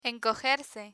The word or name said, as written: encogerse